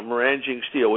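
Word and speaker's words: maraging: We know that they're centrifuge technology, because we've managed to prevent them from getting things like meranging steel. Is that how you pronounce it? – Mm-hmm. – Uh-huh, meranging steel, which is a very high-quality steel, which is used in things like missiles and nuclear centrifuges.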